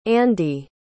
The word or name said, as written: andi